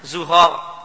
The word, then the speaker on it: Zughar: Di catatan kaki, Zughar itu wazannya Zufar dan Surak. Jadi bacanya Zughar.